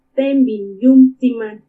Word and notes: Témbini ka iumu tsimani: Purépecha

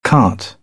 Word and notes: cart: British English; ɑː

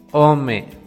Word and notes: Ome: Náhuatl

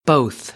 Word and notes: both: American English; oʊ

